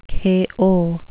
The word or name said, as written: kayo